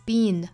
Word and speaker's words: been: been 美音发 been，松一些就可以了。